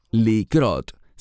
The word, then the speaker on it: likrot: Me refiero a una palabra que se deletrea Kuf Resh Hei y se pronuncia Likrot, significa pasar o acontecer.